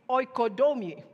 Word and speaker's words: oikodome: The Greek word for edify is oikodome. Oikodome. That's how you pronounce it.